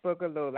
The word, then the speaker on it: Boogaloo: It is Boogaloo, like the dance.